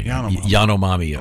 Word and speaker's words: Yanomami: I may have put an extra syllable. I think it's Yana. Yanomami, sorry. – Yanomami.